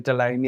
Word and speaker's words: Dillane: So, am I pronouncing it right? Stephen Delane? – I've been going with Delane, yeah.